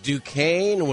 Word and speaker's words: Duquesne: Against Duquesne with the silent S.